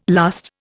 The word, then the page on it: lust: Indian